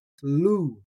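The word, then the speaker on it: lu (λου): Today's Greek root is the Greek root spelled with lambda, omicron, upsilon, pronounced lu, lu.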